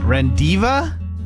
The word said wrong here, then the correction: rendezvous: Divu? Rendezvous. Used his brain.